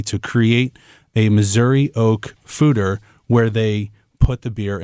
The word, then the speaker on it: foeder: This is a mixed fermentation ale that has been aged in oak foeders. Now, that's an interesting word. I say foeder, but it's actually pronounced fooder. So I even messed it up, but it's F-O-E-D-E-R.